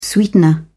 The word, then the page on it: sweetener: British English